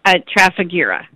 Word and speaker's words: Trafigura: Trafigura. – Trafigura Run for the House. I'm not familiar with this particular foundation or the run. Can you get us all updated on that? – Sure. Yeah, the Trafigura Run, this will actually be our 13th annual Trafigura Run, and it was started.